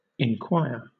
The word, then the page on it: enquire: British English